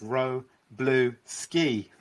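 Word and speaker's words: Wroblewski: So I remember a few years ago I was at a conference with Rook, Rook, Luke Roblewski. Roblewski. He said the way he pronounced his name is Ro Blue Ski. That's how I remember it.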